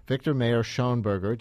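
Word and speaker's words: Viktor Mayer-Schönberger: Victor Mayor Schoenberger, did I say your name right? – Yes, absolutely.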